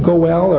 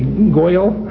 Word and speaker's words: goel: The word for kinsman there is the Hebrew word goel, or the Yiddish pronunciation might be goyel.